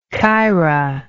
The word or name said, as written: Kyra